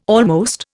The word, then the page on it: almost: Indian